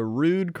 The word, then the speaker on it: Rude: Well, since we're talking about rude people today, I thought we'd head on down to Rude Croatia. – I don't know that that's how it's pronounced, but okay. – It is. R-U-D-E. Rude.